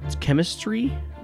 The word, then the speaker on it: Khemmistry: Anyway, they say it's chemistry, but it's spelled K-H-E-M-M-I-S-T-R-Y.